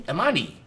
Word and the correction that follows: Iommi: Black Sabbath's guitarist, Tony Amoni. I pronounced it, I'm sorry. Hey, I grew up with Black Sabbath. – Tony Iommi. Yeah, but you know Tony Iommi.